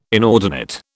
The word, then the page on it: inordinate: British English